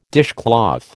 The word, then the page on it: dishcloth: American English